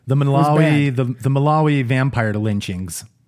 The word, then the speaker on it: Malawi: Malawi. – Malawi.